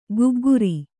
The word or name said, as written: ಗಗ್ಗರ